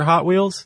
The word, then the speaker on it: Hot Wheels: And apparently, if you go look at the old original commercials for Hot Wheels. – Do they say that? – That's how they pronounce it. Hot Wheels. – Oh, instead of Hot Wheels? – Yeah. The emphasis is on the hot.